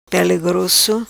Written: Pele Grosso

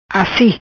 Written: Assi

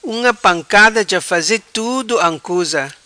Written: Unga Pancada

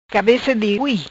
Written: Cabeca di hui

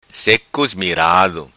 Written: Seco Ismirado

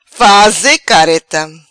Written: Faze careta

